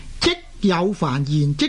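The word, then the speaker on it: 啧有烦言: 咁呢呢个字嘅正確嘅读音呢，就係、是、即有凡言，即係同堆积嘅即字呢同音。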